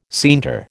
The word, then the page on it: scienter: American English